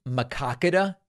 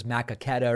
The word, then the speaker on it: Maquoketa: I guess it's Maquoketa is the way it's pronounced, Iowa. And I thought it was Maquoketa or something like that.